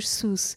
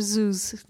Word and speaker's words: Seuss: E acho que ele andava a ser gozado por alguém porque ele não sabia dizer bem, pronunciar bem Dr. Seuss. Ele dizia mais Dr. Zeus, Zeus, Zeus.